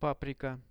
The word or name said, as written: paprika